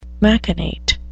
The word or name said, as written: machinate